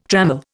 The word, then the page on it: trammel: Indian